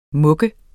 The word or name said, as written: mug